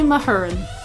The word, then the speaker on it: Mahurin: And this month the book was Serpent and Dove by Shelby Mahurin. That's how it's said. But then again, she also has an American accent. She's like Shelby Mahurin. So maybe it's the Australianized version.